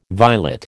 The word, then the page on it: violet: American English